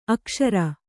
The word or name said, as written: ಅಕ್ಸರ